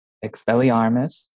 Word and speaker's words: Expelliarmus: Wingardium Leviosa, Expelliarmus, Petrificus Totalis.